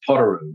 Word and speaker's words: Potoroo: Is that how you say it? Is it Potoroo? – Well, that just becomes a dialect. It is Potoroo.